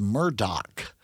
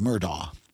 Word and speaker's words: Murdaugh: But it's D-A-U-G-H, isn't it? Like the D-A-U-G-H, thing yes. – The most people seem to be pronouncing it as though it was Murdoch, which doesn't seem to follow through from, I would pronounce it as Murdaugh.